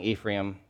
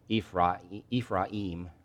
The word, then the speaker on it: Ephraim: So Joseph, man, he immediately takes off to see his father, and he grabs his two boys, Manasseh and Ephraim, which I'm pronouncing Ephraim because it's easier, but it's actually Ephraim.